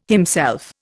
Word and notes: himself: Indian

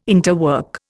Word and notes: interwork: Indian

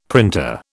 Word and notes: printer: British English

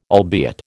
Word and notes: albeit: American English

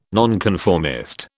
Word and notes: nonconformist: British English

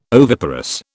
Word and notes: oviparous: British English

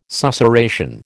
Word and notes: susurration: American English

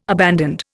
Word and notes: abandoned: Indian

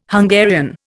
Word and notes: Hungarian: Indian

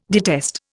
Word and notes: detest: Indian

